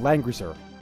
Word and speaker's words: Langrisser: They came out with a remake of a popular Japanese RPG series called Langrisser. I'm not sure if I'm saying that correctly. – Yes, it's on phones and 3DS.